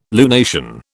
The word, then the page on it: lunation: British English